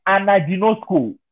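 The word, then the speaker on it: anaginosko (ἀναγινώσκω): The word read in have you not read means, in the Greek, is anaginosko. Let me spell it for you. A-N-A-G-I-N-O-S-K-O. Anaginosko.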